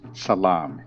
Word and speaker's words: tselem: Remember, within us, that word image literally is pronounced salam, but it's with a T-S-E-L-E-M. It means a shadow.